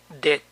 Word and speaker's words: debt: Debt. Debt. La B no se pronuncia.